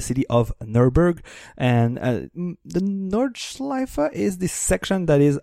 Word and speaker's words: Nordschleife: So the I'm butchering this its German name, but the Nordschleife. – No, that sounds right to me.